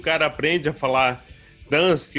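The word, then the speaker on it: Gdańsk: Ele, não, não é Gdansk, é Dansk. O uhum. G é mudo.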